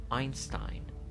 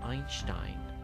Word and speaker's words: Einstein: در انگلیسی ولی اینستاین اینستاین در آلمانی اینشتاین یعنی تقریبا فقط سه تبدیل به ش میشه اینشتاین